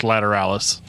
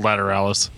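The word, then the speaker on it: Lateralus: Speaking to your comment about Tool, the album is pronounced Lateralis. – Okay, cool. – I looked up a YouTube video of the band talking about it. It's called Lateralis.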